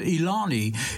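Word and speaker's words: Eliane: It looks like Elaine. I think she pronounces it Ilani.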